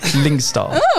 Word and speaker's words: Lyngstad: Lingstad. – Mm, you say it then. Oh. – Anne Frid. Lingstar. – Oh, well done.